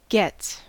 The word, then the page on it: gets: American English